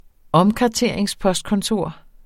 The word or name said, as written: omkarteringspostkontor